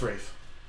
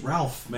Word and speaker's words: Ralph: The motherfucker's name is Ralph, man. – I believe he pronounces Rafe. I don't care how he pronounces it.